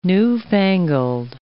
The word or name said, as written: newfangled